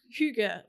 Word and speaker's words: hygge: Planning ahead, like what are the things I can do to like light the house and make it look super hygge, or as Rich calls it, hygge. – Can I, it looks like because it's spelled H-Y-G-G-E and it looks like hygge, so I'm making the house super hygge because planning ahead, because this is it for us.